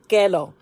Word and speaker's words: Kellogg: My first and only pet was a golden retriever named Kellogg, as we pronounce it, Kelo.